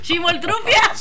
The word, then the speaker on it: Chimoltrufia: No, tampoco, pero me imagino que debe cantar así, déjame ver, como entre la chimoltrufa y chimoltrufa. – ¿O cómo se dice? Chimoltrufa. – Chimoltrufia. – Chimoltrufia.